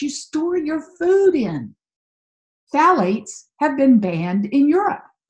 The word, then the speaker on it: phthalates: Number three heavy hitter is phthalates. Now I'll spell that one for you because it's a little tricky. It's P-H-T-H-A-L-A-T-E-S. Phthalates. The P-H is silent.